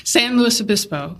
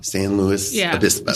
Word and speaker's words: San Luis Obispo: And at that time, my parents had purchased a second home in San Luis Obispo, California. So I still can't pronounce that. – San Luis Obispo. – San Luis Obispo.